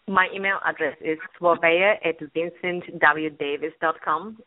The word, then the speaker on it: Slaveia: Slaveia, I would like you to stay on the line and Raj stay on the line.